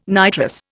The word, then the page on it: nitrous: Indian